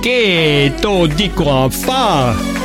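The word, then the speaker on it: Che t'o dico a fa: Que do dico a fa. Jetzt haben wir es richtig ausgesprochen.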